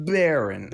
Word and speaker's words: Barrens: Barrens.